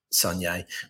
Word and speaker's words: Saulnier: Before he was a filmmaker, director Jeremy Saulnier, did I say that right? – Yeah. – Saulnier was heavily influenced by the music subgenre of 80s skate punk.